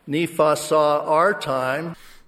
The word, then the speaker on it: Nephi: Instead of Nephi, he says Nepha.